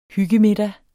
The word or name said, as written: hyggemiddag